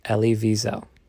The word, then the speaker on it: Elie Wiesel: Elie Wiesel.